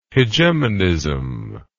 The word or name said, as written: hegemonism